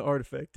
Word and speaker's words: RTFKT: But yeah, I think overall, I think the project between Nike and Artifact really, they did really well. I kind of like the whole vial. – Props to you on the Artifact too.